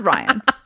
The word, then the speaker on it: Rian: Rian. – Rian Johnson. It's pronounced Rian.